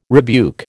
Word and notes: rebuke: American English

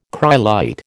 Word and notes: cryolite: American English

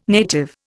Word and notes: native: Indian

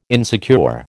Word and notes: insecure: American English

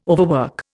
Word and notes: overwork: Indian

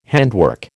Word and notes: handwork: American English

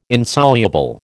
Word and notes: Insoluble: American English

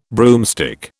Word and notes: broomstick: British English

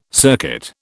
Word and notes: circuit: British English

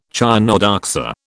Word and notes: chionodoxa: British English

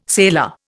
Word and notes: sailor: Indian

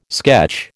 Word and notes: sketch: American English

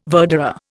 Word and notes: verderer: Indian

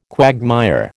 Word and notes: quagmire: American English

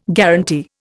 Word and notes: guaranty: Indian

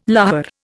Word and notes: lahar: Indian

